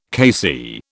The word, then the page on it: KC: British English